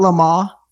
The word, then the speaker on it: Le Mans: call it Le Mans. – Le Mans. – Le Mans. – There's an N. I'm already not pronouncing the S. You can't make me not pronounce. – I know, but it's Le Mans.